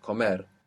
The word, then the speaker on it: comer: Accent on the end. Comer. To be for states.